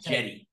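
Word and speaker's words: Cedi: It's pronounced with a J, by the way, like Jedi, Jetty.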